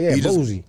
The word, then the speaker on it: Boosie: Boosie.